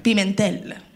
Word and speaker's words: Pimentel: How do you say your last name, by the way? – Pimentel. – Pimentel. – Correct.